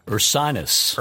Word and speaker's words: Ursinus: Ursinus.